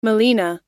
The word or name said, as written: melena